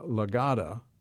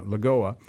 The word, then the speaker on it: Lagoa: And then the third, Barbara Lagoa, right? Is that how you say it? – That's correct. – Yeah, Lagoa from Florida.